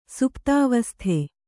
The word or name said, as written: ಸುಪ್ತಾವಸ್ಥೆ